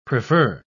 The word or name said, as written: prefer